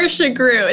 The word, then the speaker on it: Sugrue: Noor Sugrue.